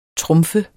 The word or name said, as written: trumf